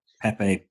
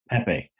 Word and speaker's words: Pepe: I noticed um, the commentator on BT Sport was calling Pepe Pep, which is the Portuguese pronunciation. But throughout his career, he's always been known as Pepe uh, when he was playing in Spain and stuff.